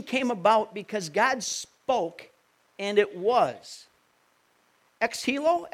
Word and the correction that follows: ex nihilo: ex nihilo. How does that go, Pete? How do you say that word? Ex nihilo.